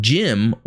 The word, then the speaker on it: GM: The GM, as far as I know, is pronounced GM. GM. Because it's an RGM type suit. But Jim, or Gem, is a nickname abbreviation.